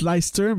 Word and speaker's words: Leicester: ou à Leicester, je devrais plutôt dire. Ça se prononce Leicester, même si ça, en fait, ne vous fiez pas à la façon dont ça s'écrit.